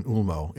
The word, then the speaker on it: Ulmo: And then finally, if the O is the last letter, if it's at the end of the word like in Ulmo, it's a long O as in English, foe.